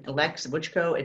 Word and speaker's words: Leks Vucho: My name, Leks Vucho, it's spelled differently, L-E-K-S, V-U-C-K-O. But even if you type L-E-K-S with a V, it comes up.